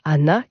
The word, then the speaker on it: ghana: Tous ces chants traditionnels portent le nom de Hana, qui s'écrit G-H-A-N-A, ça vient de l'arabe.